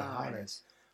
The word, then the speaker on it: Lajares: Go on, say it properly, Lajares.